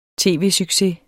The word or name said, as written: tv-succes